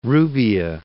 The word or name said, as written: Rubbia